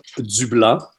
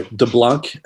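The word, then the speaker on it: DeBlanc: Damita Jo Dublanc, uh, her full name, uh, very French, or de Blanc, uh, like you, you Americans like to pronounce it. we don't pronounce the C in French, by the way.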